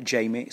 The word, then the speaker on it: Jaime: So, my name is Paul Jamie Sunderland. Jamie is spelt differently to normal though. It's spelt J-A-I-M-E.